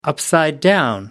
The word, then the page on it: upside down: American English